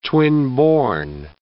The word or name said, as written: twinborn